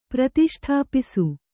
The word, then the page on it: ಪ್ರತಿಷ್ಠಾಪಿಸು: pratiṣṭhāpisu